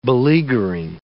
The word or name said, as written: beleaguering